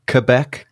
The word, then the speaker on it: Quebec: Is that how you say it? Quebec, Canada. – Quebec.